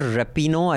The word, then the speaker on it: Rapinoe: In case you're wondering who we're talking about, we're talking about Rapinoe. I think that's how you pronounce her name.